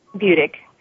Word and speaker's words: Budig: Budig.